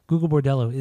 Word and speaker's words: Gogol Bordello: Gogol Bordello is just straight fun.